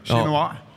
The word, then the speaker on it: Chinois: Det är svårt att uttala det. Chinoa. – Ja. – För det stavas nämligen Chinois.